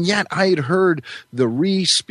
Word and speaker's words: Rhys: How is your name correctly pronounced? – It is correctly pronounced John Rhys Davies.